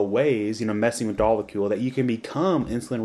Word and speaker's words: dolichol: And this eventually gets turned into a molecule called dolichol. And how would you pronounce that? – Farnesyl-PP and dolichol. I think, yeah, dolichol. – Dolichol? Right. Okay, yeah, because it's a weird-looking word.